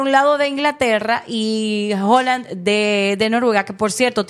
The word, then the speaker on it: Haaland: Tenía 20 y tantos años sin participar y nos deja de sorpresa pues un jugador de la talla, de la categoría de Haaland, o Holland, que es la pronunciación correcta de su apellido.